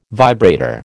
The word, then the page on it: vibrator: American English